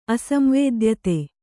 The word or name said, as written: ಅಸಂವೇದ್ಯತೆ